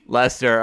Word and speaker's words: Leicester: Is that how you pronounce that? Leicester? – Leicester.